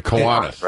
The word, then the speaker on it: Kiwanis: Is it Kiwanis or Kiwani? – The Kiwanis. – Right? Kiwanis. – Yeah. – Kiwanis. All right. I had it right the first time.